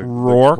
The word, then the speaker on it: Rourke: Rourke. Mickey Rourke? – Like York.